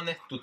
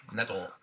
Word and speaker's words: Netto: Du uttalar netto tysk. Netto.